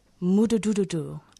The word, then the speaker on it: Mududududu: It's Mududududu. And it's the Shona word for a motorbike. And as you can hear, it's the sound of the motorbike starting up.